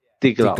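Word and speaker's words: Die Glocke: So it says Die Glock. Is that how you say it? – Yeah, Die Glock. Die Glock. Yeah.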